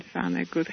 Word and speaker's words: Vonnegut: And there was a fantastic article written by Kurt, actually, Kurt Vonnegut, how Americans pronounce it, in Utney Reader.